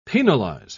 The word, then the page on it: penalise: British English